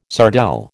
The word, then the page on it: sardelle: American English